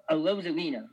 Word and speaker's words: Arozarena: Oh, Arozarena, my bad. Randy Arozarena, he was playing great. I would put him on the Mount Rushmore of Randy's. You know, Randy Arozarena, Randy Johnson, Randy Newman, Randy Quaid.